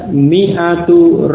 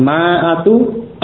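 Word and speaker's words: mi'atu (مائة): Dibaca mi'atu rajulin. Tulisannya walaupun ada alif, tetapi alif yang sesudah mim ini tidak dibaca, tidak diucapkan. Sehingga bacanya cukup mi'atu. Mi'atu pendek ya. Bukan ma'atu, juga bukan mi'atu. Karena alif itu tidak dibaca, tertulis tetapi tidak dibaca. Jadi mi'atu.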